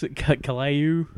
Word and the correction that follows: Caillou: Oh, Caillou? – Caillou. Except for Caillou.